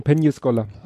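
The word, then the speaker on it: Peñíscola: Nein, das wird ausgesprochen Penny Scholar.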